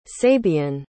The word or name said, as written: sabian